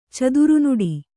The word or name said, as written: ಚದುರುನುಡಿ